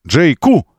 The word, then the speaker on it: JQ: Он называется JQ. Именно на Q ударение. Как, э, э, как Q. Q вот это. JQ.